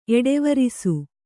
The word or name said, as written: ಎಡೆವರಿಸು